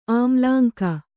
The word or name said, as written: ಆಮ್ಲಾಂಕ